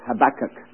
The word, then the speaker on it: Habakkuk: If you are a Hebrew scholar, you probably call it Habakkuk. But many people call it Habakkuk. I call it Habakkuk, not because I'm a Hebrew scholar, but because it's the right pronunciation.